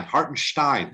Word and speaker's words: Hartenstein: Hartenstein. There's a CH in there.